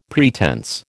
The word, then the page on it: pretense: American English